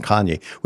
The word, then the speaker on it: Kanye: Honestly, since we did our one or two episodes on Kanye, which is how I learned how to say his name, If what, you remember, I thought he was Cain.